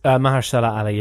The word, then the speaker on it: Mahershala Ali: And while he's there, a guy called Juan finds him, who is played by Mahershala Ali, I think it is. – Mahershala Ali, yes.